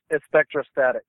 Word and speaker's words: Espectrostatic: It's pronounced Espectrostatic. – Espectro, like specter, like a ghost, right? – Yeah, like ESP, Espectrostatic.